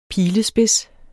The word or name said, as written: pilespids